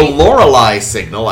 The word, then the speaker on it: Lorelei Signal: The Lorelei Signal, I think I'm pronouncing that right.